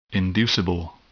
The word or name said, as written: inducible